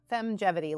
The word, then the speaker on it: Femgevity: Femgevity, is that right? – Yeah, Femgevity, like feminine longevity.